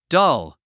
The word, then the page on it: dull: dʌ’l; ダル